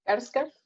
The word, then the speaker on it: Skarsgård: ¿Bill Skarsgård? – Skarsgård, sí.